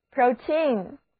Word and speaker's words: protein: 今天的最后一个单词叫做 protein， protein 字母 O 读它的本音 O，而字母组合 EI 则读作长的 E，单词拼写为 protein。